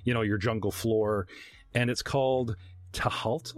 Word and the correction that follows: Tual: Is this what I'm pronouncing this properly? – It's pronounced Tual.